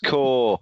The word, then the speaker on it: Corps: It's pronounced core. Yeah, like the Army Corps. The Marine Corps. – Is that not C-O-R-E? – Okay.